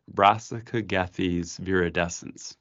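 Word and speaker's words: Brassicogethes viridescens: The Latin name, I'm going to try it, Brassica gethes viridescens. Did I say that right? – Correct, yes.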